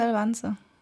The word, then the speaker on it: Elvanse: Elva Spricht man das echt Elvanse? Bei uns in der Klinik sagen sie Elvanse. Ich finde Elvanse klingt komisch, aber okay.